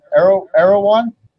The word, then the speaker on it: Erewhon: Warlords of Erewhon. Erewhon. It's nowhere spelled backwards.